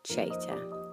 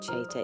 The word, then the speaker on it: Chater: Chater. C-L-A-R-E, the letter J for jungle. And my surname Chater is C-H-A-T-E-R.